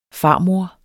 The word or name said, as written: farmor